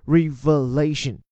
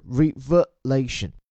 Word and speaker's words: revelation: 它是名词形式的揭露，它的动词叫 reveal，但是 reveal 呢，就是把这里的 v e l 后面那个 a 挪到前面去，而且发音也不同。动词 reveal，而这里是名词 revelation，这个第二个 e 字母还把它发成 a 啊，所以是 revelation。